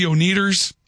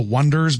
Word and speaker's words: Oneders: Another texter writes, it's pronounced the Oneaters. That's a joke. The band, that's the fictitious band in That Thing You Do. – Oh, okay. – They called themselves the Wonders, but they made it O-N-E-D-E-R-S.